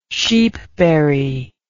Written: sheepberry

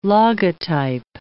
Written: logotype

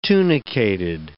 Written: tunicated

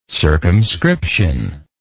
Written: circumscriptively

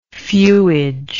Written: fuage

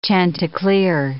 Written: chanticleer

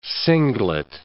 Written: singlet

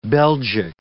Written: Belgic

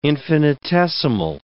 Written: infinitesimal

